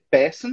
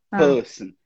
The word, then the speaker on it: person: Essa aí, ó, é um som um pouquinho mais fechado. Ao invés de ser person, você vai falar person.